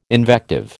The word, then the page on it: invective: American English